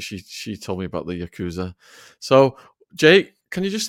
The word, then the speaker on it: Yakuza: Is it pronounced Yakuza? – It is pronounced Yakuza. That is okay.